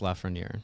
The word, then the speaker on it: Lafreniere: I can never say his name right. – Alexis Lafreniere. – I said it right. – Pretty good.